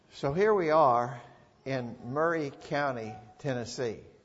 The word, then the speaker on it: Maury: Now I'm telling you, Murray County, you better say that right or we're going to know that you ain't from around here. Murray County, Tennessee. M A U R Y. M A U R Y. And for all the world, that looks like that ought to be Maury, maybe.